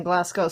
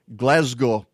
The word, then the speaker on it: Glasgow: And one of the articles I read said the natives over there are getting used to people saying Glasgow, which is how Americans pronounce it. And it's, I think it's sort of jarring, to them when they hear Glasgow. it reminds me of a cow made of glass.